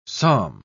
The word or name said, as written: psalm